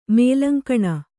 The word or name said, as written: ಮೇಲಂಕಣ